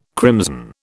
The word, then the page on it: crimson: British English